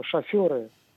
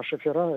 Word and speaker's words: шоферы: Вот мне все же кажется, что шоферы... правильно говорить. А шофера – это такой разговорный вариант.